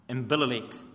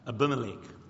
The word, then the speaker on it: Abimelech: It's taken 20 chapters to even talk about prayer, where God says to a bloke, he's a king and his name is Abimelech. I didn't say that right. Abimelech, that's right.